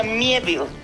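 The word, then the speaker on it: Miéville: El apellido es un apellido francés. Entonces se mezcla ahí la pronunciación del inglés británico con el acento este particular que tiene en la E, entonces se pronuncia Mieville.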